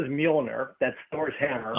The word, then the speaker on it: Mjolnir: Yeah, is the J silent or what? Do you? – Kind of. It's almost like, is that Mjolnir? Almost like mew cat. – Yes. – You know, like a cat mew. It's an odd pronunciation. – So we do hear the M.